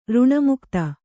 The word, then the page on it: ಋಣಮುಕ್ತೆ: kannada